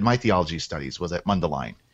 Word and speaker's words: Mundelein: Mundelein, is that the way you pronounce it? – Mundelein.